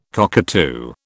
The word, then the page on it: cockatoo: British English